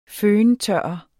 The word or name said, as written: føntørrer